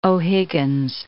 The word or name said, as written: O'Higgins